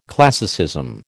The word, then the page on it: classicism: American English